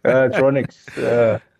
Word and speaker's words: Ronex: Is the X silent? Because these nowadays, there's lots of silent letters. – Yeah, it's actually. I hear you. Ronex.